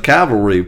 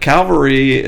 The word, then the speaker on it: cavalry: It's like Calvary is where Jesus was crucified. Right. And cavalry is what you're trying to say, dummies.